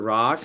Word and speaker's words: Rak: My name's Dr. Rock, that's spelled R-A-K, pronounced R-O-C-K.